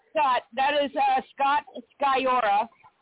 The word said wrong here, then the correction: Sikora: Did I pronounce that name right? – Scott Sikora. – Sikora.